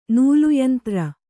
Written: ನೂಲುಯಂತ್ರ

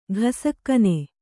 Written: ಘಸಕ್ಕನೆ

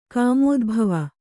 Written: ಕಾಮೋದ್ಭವ

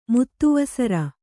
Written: ಮುತ್ತುವಸರ

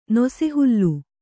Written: ನೊಸೆಹುಲ್ಲು

